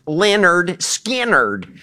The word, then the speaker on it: Lynyrd Skynyrd: Lynyrd Skynyrd, pronounced Lynyrd Skynyrd.